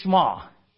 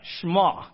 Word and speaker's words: Shema: Everyone say Shema. Right? Shema. That's how it's pronounced. No E in there. It's just Shema.